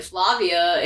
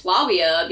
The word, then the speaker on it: Flavia: And they're talking a little bit, and then he corrects her pronunciation, and he's like, FYI, your name isn't Flavia, it's Flavia, because the V's in Latin are pronounced like W's.